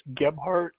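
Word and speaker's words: Gebhart: James Gebhardt, last name is spelled GEB as in boy, HART as in Tom.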